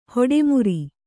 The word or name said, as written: ಹಠಮಾರಿ